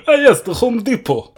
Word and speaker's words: Depot: Interesting that you picked two different pronunciations of depot. – I went depot when I was saying it, but then when I was doing the quote, I decided to pronounce it as a Californian might.